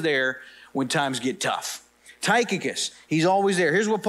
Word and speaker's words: Tychicus: The first name that Paul references here is a guy named Tychicus. Tychicus. Now, some people pronounce it Tychicus. I'm not going to do that. Sounds weird. I'm going to go with Tychicus, all right?